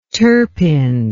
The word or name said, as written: turpin